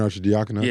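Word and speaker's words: Arcidiacono: Ryan Archidiakono.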